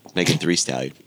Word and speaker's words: Megan Thee Stallion: I have to pronounce the extra E. I don't know why, but I'd have to. – I mean, it's in her name. – Otherwise, it looks like three.